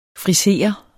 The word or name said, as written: friser